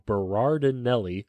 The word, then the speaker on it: Berardinelli: James Bertinelli, or Berardinelli, I always say this guy's name wrong, Berardinelli,